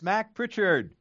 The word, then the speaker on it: Mac Prichard: Is that how you say it? Mac Prichard? – No, it's Mac Prichard.